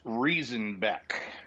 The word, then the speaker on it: Riesenbeck: So yeah, so it's Riesenbeck.